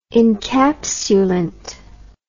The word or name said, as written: encapsulant